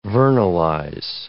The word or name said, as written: vernalize